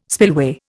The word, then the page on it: spillway: Indian